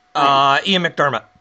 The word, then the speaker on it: Ian McDiarmid: Ian McDiarmid.